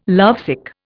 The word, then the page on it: lovesick: Indian